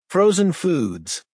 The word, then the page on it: frozen foods: American English